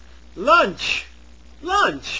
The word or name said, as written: lunch